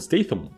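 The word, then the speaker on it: Statham: Да, он не Стэтхэм, он Стейтхэм. Ну, TH, вы же знаете, как читается, вот оно именно так и читается в его фамилии.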